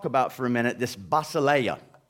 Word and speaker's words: basileia: The Greek word for kingdom is basileia. Did I get the accent right? Basileia.